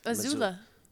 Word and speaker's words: Azula: No, Azula. – It's like Missoula. – Missoula, but Azula. – People say Z's, but it's... – S's. – It is S's, but you're pronounced them Z's.